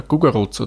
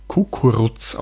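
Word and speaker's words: Kukuruz: nämlich der Gugurutz oder Kukurutz auch geschrieben. Je nachdem, von welcher Gegend man kommt, wird entweder das mit Gustav ausgesprochen oder mit Konrad. Geschrieben wird es aber immer mit Konrad.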